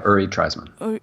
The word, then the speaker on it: Treisman: And in your book, you mentioned one particular guy called Uri Treisman. Is that how you pronounce his name? – Yeah, Uri Treisman.